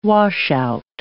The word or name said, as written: washout